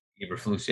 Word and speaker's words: Eberflus: I don't know too much about Eberflus or however you pronounce his name, but Eberflus, yeah. – Eberflus.